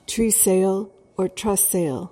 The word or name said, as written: tresayle